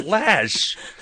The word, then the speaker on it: Loesch: But Dana Loesch, who must explain on air why her name is spelled L-O-E-S-C-H and is called Lash.